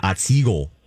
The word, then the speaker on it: Otsego: Well, my favorite part about Otsego is just pronouncing it like a Minnesotan. Otsego.